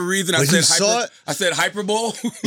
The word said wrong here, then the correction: hyperbole: You were like, shit, did Elon Musk invent something? – It's hyperbole. You know what I'm saying?